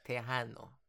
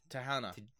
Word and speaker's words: Tejano: Pronunciation needs a wee bit of work. It's Tejano. – That's what I said. I said Tejano. – No, you said Tejana. – Tejana or something. – I did not say Tejana. I said Tejana.